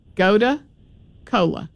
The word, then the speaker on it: Gotu Kola: Gotu Cola, which is spelled G-O-T-U-K-O-L-A. Gotu Cola.